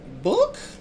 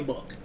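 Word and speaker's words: Bouc: I'm uh, so Poirot has that like super thick Belgium accent, and his name is spelled B O U C. I just call him Book because I don't know how else to pronounce it because everybody else calls him something different.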